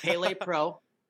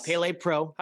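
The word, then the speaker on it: Palē: Pele Pro. It's on my shirt too. – Nice. – Pele Pro. – How do you spell it? – It's P-A-L-E, but it's the E with the little dash.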